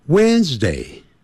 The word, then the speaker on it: Wednesday: Tuesday 那礼拜三呢我们 it's 先不念 Wednesday 那个 WD 那个 D 不念出来的 When 更不要念成 Wednesday、